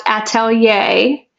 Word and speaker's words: atelier: It is pronounced atelier, and it is A-T-E-L-I-E-R.